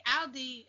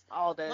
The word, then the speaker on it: Aldis: main event, like pay-per-view, and Nick Aldi, is that how you say his name, or Aldis? – Aldis.